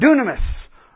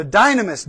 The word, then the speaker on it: dunamis: This is where we get our word dynamite. Is dynamis as well. The way to pronounce it. So dynamis, dunamis, dynamite power.